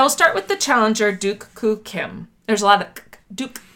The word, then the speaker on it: Duk Koo Kim: So you said it's pronounced Duke Duk Koo Kim.